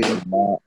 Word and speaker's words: Ayton: That, you know, that dude, the center, I hope I'm saying his name right, Ayton. – Yeah, DeAndre Ayton.